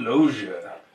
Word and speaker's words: Loggia: Robert Loggia, who plays General William Gray. That's how you have to say his name.